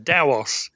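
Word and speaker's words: Taos: It's pronounced Daos. – Daos, right. Okay, right. I guess I pronounced it wrong for 25 years. – T-A-O is a Chinese word, and it's pronounced Dao. It's right. Supposed to be an unaspirated T, so it sounds like a D.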